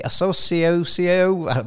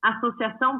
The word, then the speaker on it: Associação: The Associação, that's totally wrong, Brasileira de Cricket, ABC, and was founded in 2001. Give me the right pronunciation. – Associação Brasileira de Cricket. – That's the one.